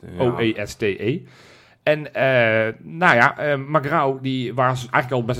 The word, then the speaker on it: Magrão: Magrão? – Nou, perfect. – Echt waar? – Ja. Gerson Magrão, want zo spreek je het uit.